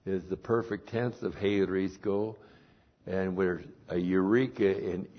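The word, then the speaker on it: heurisko (εὑρίσκω): And then we have the verb heurisko. And we've had this verb a lot of different places in the Bible. H-E-U-R-I-S-K long O.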